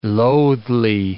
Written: loathly

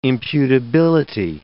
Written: imputability

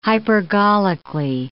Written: hypergolically

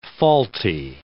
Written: faulty

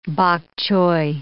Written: bok choy

